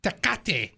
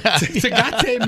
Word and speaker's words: Tecate: border in Tecate. – Well, there you go. – Did I say it right? – There, yeah, absolutely.